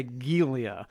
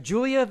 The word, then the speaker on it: Giulia: Which is good that we talked about this before we went on the air, because it's spelled G-I-U-L-I-A, which I was going to say Gilia. But it's Julia, the Poison Queen of Palermo.